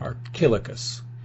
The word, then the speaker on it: Archilochus: Yes, Archilochus is, I believe, how, because Ryan had helped me pronounce him. – Good. – Archilochus.